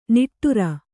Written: ನಿಟ್ಟೂರಿ

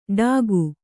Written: ಡಾಕು

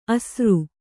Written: ಆಸರು

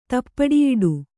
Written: ತಪ್ಪಡಿಯಿಡು

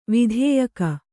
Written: ವಿಧಾಯಕ

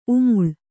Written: ಉಮ್ಮುಲು

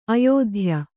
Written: ಆಯುಧೀಯ

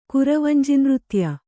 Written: ಕುರವಂಜಿನೃತ್ಯ

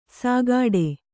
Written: ಸಾಗಾಡೆ

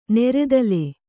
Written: ನೆರೆದಲೆ

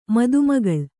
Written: ಮದುಮಗಳು

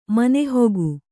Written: ಮನೆಹೊಗು

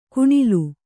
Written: ಘಣಲು

